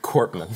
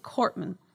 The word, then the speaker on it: Korpman: Korpman. – Cort- Korpman.